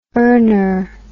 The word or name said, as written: earner